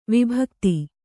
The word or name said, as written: ವಿಭಕ್ತಿ